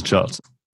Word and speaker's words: JWT: The actual spec, I think, even says you pronounce it as a JOT.